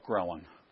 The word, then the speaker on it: ghrelin: level of ghrelin. Did I say that right? Yeah.